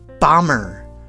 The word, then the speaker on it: bomber: B-O-M-B-E-R, bomber, bomber. D-E-B-T-O-R, detour, detour. P-L-U-M-B-E-R, plumber, plumber.